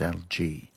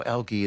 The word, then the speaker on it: algae: But as we get the Latin word, we've got a rule which says after A-E, it changes the pronunciation of the preceding consonant. So it's algae. But they allow algae as well.